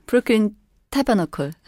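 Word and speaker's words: Brooklyn Tabernacle: 브루클린 타버나클. – 네. 타버나클이라고 읽어야 되나? – 네.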